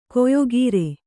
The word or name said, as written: ಕೊಯ್ಗುರಿ